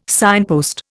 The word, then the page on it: signpost: Indian